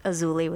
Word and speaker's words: Azuli: Yeah, so in Spanish it would be pronounced more with an S, Azuli. In English, Azuli with a Z.